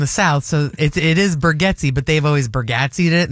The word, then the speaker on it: Bargatze: No, it's Bargatze. – Are you sure? – Yes. – That's how he pronounces it? – Yeah, and there's a whole feel. That's the correct pronunciation.